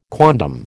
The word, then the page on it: quondam: American English